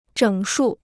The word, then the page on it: 整数: zhěng shù